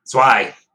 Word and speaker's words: Zwei: Zwei? How do you say this one? – Zwei. Yeah, it's Zwei? German for two.